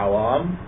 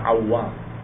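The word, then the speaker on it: Awwam: Tadi saya membacanya awam, tetapi di sini ada tasdidnya awam.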